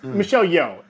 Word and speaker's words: Michelle Yeoh: and Michelle Yao for Everything, Everywhere, All at Once. Michelle Yeoh is how her name is pronounced. Apologies, I mispronounced her.